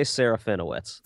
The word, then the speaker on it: Serafinowicz: It's Peter, is it Serafinowicz? – I always say Serafinowicz. That's how, yeah.